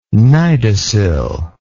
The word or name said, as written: cnidocil